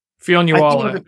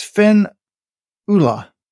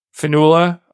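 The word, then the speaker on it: Fionnuala: I would say it's Finn Ula. – Finula? Okay.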